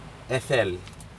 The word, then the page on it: edel: Aneityum